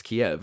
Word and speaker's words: Kyiv: And I thought that was really interesting because in my life, it had always been pronounced Kiev. It wasn't until this last few months or weeks that I've heard it pronounced Kyiv.